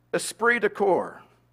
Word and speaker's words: esprit de corps: The phrase is esprit de corps. Esprit de corps. I'll spell it for you. E-S-P-R-I-T.